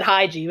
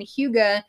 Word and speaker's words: hygge: I said hygge, but it's not hygge. – So hygge is spelled H-Y-G-G-E, and it is some Danish kind of keys to happiness.